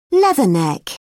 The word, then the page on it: leatherneck: British English